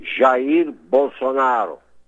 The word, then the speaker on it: Jair Bolsonaro: All of us in America, all of us patriots, were very interested in your president, Jair Bolsonaro. And I'd love you to give me the correct Portuguese pronunciation of your president's name. – Well, his name is Jair Bolsonaro, okay, in Portuguese.